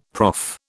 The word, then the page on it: prof: British English